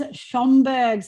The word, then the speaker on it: Schomburgk: And today we are joined by, and here we go, James Schomburgk, silent C, silent K. I got it right.